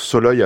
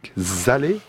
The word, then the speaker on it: Szalay: Vous m'avez donc appris, juste avant que nous commençions cette dispute littérature, que David Soloy a un nom qui se prononce Soloy alors qu'il s'écrit S-Z-A-L-A-Y, Zalé, aurais-je dit.